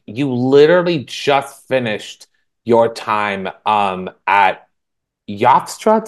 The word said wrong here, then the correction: Yeshiva: Did I pronounce that right? – Yeshiva. – Yeshiva.